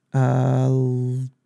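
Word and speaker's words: Lowder: I mean, the dude the Reds got, Lawler, did I say that right? Rhett, Louder.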